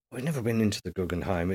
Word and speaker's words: Guggenheim: Guggenheim. – Bless you. Guggenheim.